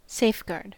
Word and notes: safeguard: American English

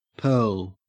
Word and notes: pearl: Australian English

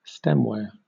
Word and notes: stemware: British English

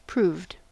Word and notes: proved: American English

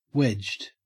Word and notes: wedged: Australian English

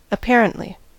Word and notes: apparently: American English

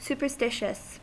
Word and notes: superstitious: American English

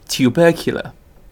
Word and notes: tubercular: British English

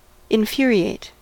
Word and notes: infuriate: American English

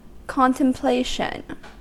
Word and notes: contemplation: American English

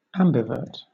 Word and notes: ambivert: British English

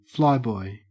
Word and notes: flyboy: Australian English

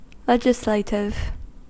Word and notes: legislative: American English

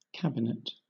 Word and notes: cabinet: British English

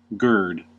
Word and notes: gird: American English

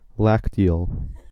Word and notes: lacteal: American English

